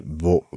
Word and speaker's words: Vought: I would have, I would have said so, but isn't that Vort Sikorsky? – Vort, Vort. Yes, I'm sorry.